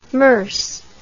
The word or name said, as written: Merse